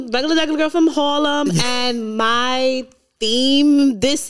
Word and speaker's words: Harlem: From Harlem. Emphasis on the H.